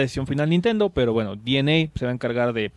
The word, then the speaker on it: DeNA: bueno, tienen una alianza en específico con la compañía llamada DNA, que se escribe Dena, pero se pronuncia DNA, que es japonesa, que tiene mucha prominencia en esto de los juegos móviles.